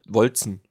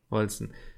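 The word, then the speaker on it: Wolcen: spricht man es aus? Wolken, Wolchen, Wolzen? – Äh, Im GameStar-Video, was ich gestern geguckt habe, hieß es Wol Wolzen. – Wolzen.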